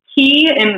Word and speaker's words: ki: So the ki in Reiki is actually the Japanese pronunciation of chi, which I had talked about earlier, like the vital energy.